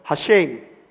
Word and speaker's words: Hashem: 这堂教多一点点，有一部分的犹太人他会读 Hashem。